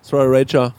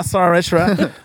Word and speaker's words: Sriracha: Wie spricht man es denn richtig aus? – Sriracha. – Ah ja, Sriracha.